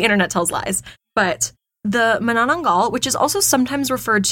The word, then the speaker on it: Manananggal: Manananggal, yes? – Yes.